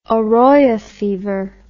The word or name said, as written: Oroya fever